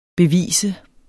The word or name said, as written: bevis